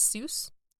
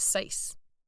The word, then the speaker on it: Seuss: You know, he didn't originally pronounce Seuss as Seuss. – Oh? – He pronounced it, I think, as Sice. – Sice. – But he eventually changed it to the pronunciation that all the American readers were using. He said himself that he wanted to consider himself a bit of an American Mother Goose. So he had it rhyme with that as Dr. Seuss.